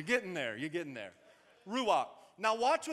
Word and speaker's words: ruach: So, the Hebrew word for the Holy Spirit or Holy Ghost is this word, ruach. Ruach. Now, when you say that word, both campuses, you have to say it like you got some steak stuck in the back of your throat, like that.